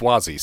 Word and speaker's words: Suozzi: Spell his name with a W, even though it's pronounced Suozzi, so yeah.